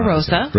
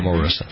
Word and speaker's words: Omarosa: So, yesterday we were talking, it was yesterday, the day before, about Omarosa. I pronounced her name. – Omarosa. – Right. Omarosa. She's suing LaToya.